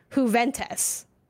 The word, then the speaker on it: Juventus: it's it's it's it's pronounced with a with a with a Y rather than H. It's Juventus, but.